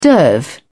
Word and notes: derv: British English

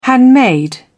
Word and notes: handmade: British English

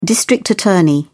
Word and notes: district attorney: British English

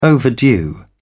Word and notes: overdue: British English

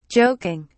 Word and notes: joking: British English

